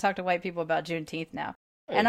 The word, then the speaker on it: Juneteenth: And she goes, "Juneteens? June what? – The what? – Yeah, like did I say it wrong?" Kind of a thing. And I was like, "Juneteenth."